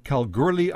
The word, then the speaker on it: Kalgoorlie: Okay, and a rather colorful history here too, Mickey, in terms of that great mining district in Western Australia, Kalgoorlie, I believe is how you pronounce the town, is that correct? – Mm-hmm.